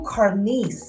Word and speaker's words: Carmiece: Google Carmiece, C-A-R-M-I-E-C-E. Again, that's spelled C-A-R-M-I-E-C-E. Last name Graves, G-R-A-V-E-S.